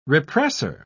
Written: repressor

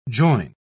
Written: join

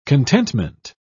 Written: contentment

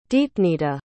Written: deepnita

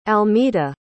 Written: almeta

